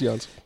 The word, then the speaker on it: Jens: I don't know how to pronounce it. It's Jans.